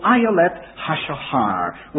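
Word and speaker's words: Ayaleth Hashahar (אַיֶּלֶת הַשַּׁחַר): So Ayaleth Hashahar was the way that he pronounced them.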